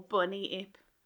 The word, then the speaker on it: Bunyip: Is it pronounced Bunyip or Bunnyip? – Bunnyip. Because bunny sounds friendly, it's like, oh, little bunny, come here.